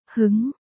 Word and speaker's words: Hưng: Đào Diệp Tiên đáp, Hưng đây đọc là, hứng, hứng, là cao hứng, Hưng Chi Thần, là nói tinh thần rất cao hứng.